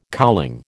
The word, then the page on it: cowling: American English